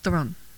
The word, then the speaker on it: Theron: And then Tully, which is supposed to be very good with Charlize Theron. I said her name right. – Oh, yes.